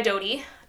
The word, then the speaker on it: Dodie: And then one of them was she by Dodie.